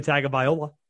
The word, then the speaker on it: Tagovailoa: Mac Jones, QB1, up against Tua Tagovailoa.